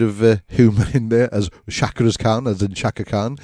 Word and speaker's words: chakras: Stage 4, how do you pronounce that? Chakras? – The chakras, yeah.